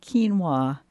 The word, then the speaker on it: quinoa: And if you're looking for more information, looking for it on the shelf, remember that it's spelled Q-U-I-N-O-A and it's pronounced quinoa.